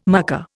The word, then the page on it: mucker: Indian